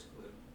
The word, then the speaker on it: Kluwe: Kluwe, how do you pronounce that? – I think it's Chris Cluey. Cluey. – That's why I say Cluey is the W-E.